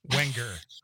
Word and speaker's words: Wenger: I knew either the N or the G. Is one of them silent, Alicia? I think I picked the wrong one. – You just gotta chunk it out like Wang. – Okay, Wenger. So there's the G is not pronounced.